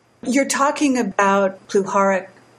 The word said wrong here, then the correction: Puharich: I'm not sure how you say his name. – Puharich, right.